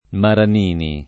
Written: Maranini

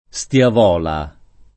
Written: Stiavola

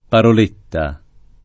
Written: paroletta

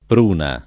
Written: pruna

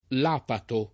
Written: lapazio

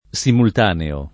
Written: simultaneo

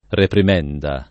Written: reprimenda

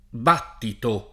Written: battito

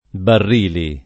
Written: Barrili

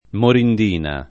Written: morindina

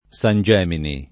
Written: San Gemini

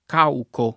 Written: cauco

